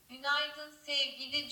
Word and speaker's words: Günaydın Sevgili: Günaydın Sevgili Joshua. Wow. Also das V und G musst du besser aussprechen, dann hört es sich genauso an.